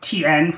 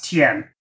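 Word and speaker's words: Tien: but I believe they go with TN for pronunciation. – Yes. – And that is how, even if we spell it T-I-A-N, it should still be pronounced TN, more or less.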